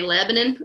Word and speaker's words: Lebanon: It's spelled Lebanon, but in Kentucky, they say Lebanon.